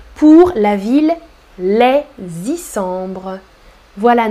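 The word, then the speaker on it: Les Issambres: Tu fais la liaison entre les et Isambres les Et tu ne prononces pas le S final.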